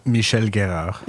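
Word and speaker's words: Michel Guérard: Michel Gerard.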